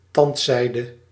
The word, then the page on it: tandzijde: /ˈtɑntˌsɛi̯də/